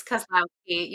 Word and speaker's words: Kozlowski: It's Kozlowski. You got it right. It's exactly as it's spelled,